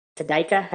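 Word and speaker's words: Tadych: See, some people think that Manitowoc did it with Bobby Dassey or Scott Tadyk, Tadyka. How do you pronounce that? – Tadyk.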